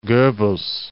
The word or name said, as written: Goebbels